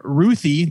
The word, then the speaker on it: Ruthye: Instead, writer Tom King focuses on Ruthie. That's how I'm choosing to pronounce it. Yeah. R-U-T-H-Y-E.